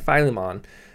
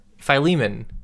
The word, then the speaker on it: Philemon: letters like Philemon.